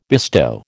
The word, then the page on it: bestow: American English